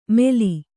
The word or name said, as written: ಮೆಲಿ